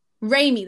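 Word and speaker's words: Raimi: Yeah, and I think um the the new director who's on it, so Sammy um I don't Sam Rami is it Rami right? – Sam Rami. – Rami, that's it.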